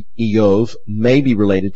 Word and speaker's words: Iyov: His name, it is believed, if it comes from Hebrew, and in fact in Hebrew it would be pronounced Iyov.